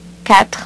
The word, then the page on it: quatre: French